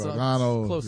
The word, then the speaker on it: Gargano: Gargano, no, Gargano. It's supposed to be, like, the old world says it, like, Gargano. Gargano is how you say it. Like, in Italy.